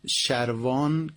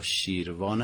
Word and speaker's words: شروان: شروان که تلفظ شروان یا شیروان هم گفته شده، شهری در قفقاز جنوبی و از قدیمی ترین دوران باستانی جز سرزمین های ایران بوده.